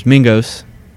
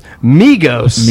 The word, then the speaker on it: Migos: He had, you know, as the, and now I can't think of the Atlanta trio of rappers, Mingos. – Mingos? Migos.